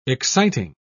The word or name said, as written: exciting